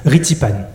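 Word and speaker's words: Rithy Panh: Pour le cinéma et la télévision, il a travaillé sous la direction de Rithi Pan. Je prononce bien ? – Presque. Allô ? Rithi Pan, okay.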